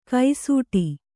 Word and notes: ಕೈಸೂಟಿ: kai sūṭi

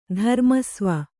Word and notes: ಧರ್ಮಸ್ವ: kannada

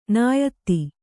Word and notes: ನಿಯತಿ: Kannada

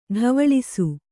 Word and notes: ಟವಾಳಿಸು: ṭavāḷisu

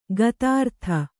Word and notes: ಗತಾರ್ಥ: gatārtha

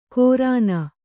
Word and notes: ಕೋರಾನ: Kannada

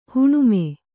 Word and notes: ಹುಣ್ಣಮಿ: huṇṇami

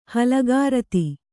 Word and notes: ಹಲಿಗಾರತಿ: kannada

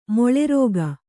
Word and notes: ಮೇಲಾರೈಕೆ: mēlāraike

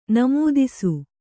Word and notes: ನಮೂದಿಸು: namūdisu

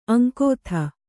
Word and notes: ಅಂಗತ: aŋgata

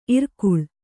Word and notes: ಇರಕಳಿ: irakaḷi

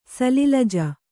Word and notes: ಸಲಿಲಜ: Kannada

